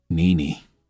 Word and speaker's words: Nene: One word. Okay, two syllables. Nene.